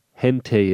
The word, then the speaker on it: hentai: hentai is.